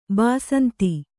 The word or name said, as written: ಬಸಂತ